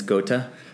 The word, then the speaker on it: Goethe: The other day I said Goethe, and it's Goethe. – Oh, yeah. – Yeah, someone corrected me.